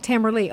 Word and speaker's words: Leigh: Tamara, I think I know your last name, but I'm L-E-I-G-H. – I'm doubting myself. – Yeah, well, L-E-I-G-H. – Okay. – Lee. – Oh, Tamara Lee.